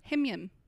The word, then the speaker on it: HIMYM: Himyam. – Himyam. – Yeah, you pronounce the acronym.